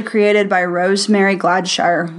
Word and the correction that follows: Gladstar: Did I say her name right? Sorry, Rosemary, if I said your last name wrong. – Gladstar.